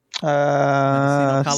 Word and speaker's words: Mendocino: Mendocino, sounds right.